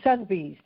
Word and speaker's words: Sotheby's: Sotheby's.